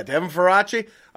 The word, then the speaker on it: Faraci: I'm saying that right? – That's correct. – Hell yeah, Devin Faraci, creator and writer at Birth Movies Death, movie critic, culture critic.